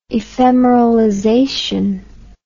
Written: ephemeralization

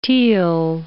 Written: teal

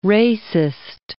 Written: racist

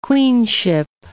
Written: queenship

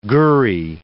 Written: gurry

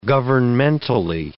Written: governmentally

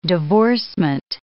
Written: divorcement

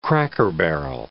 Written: cracker-barrel

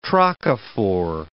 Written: trochophore

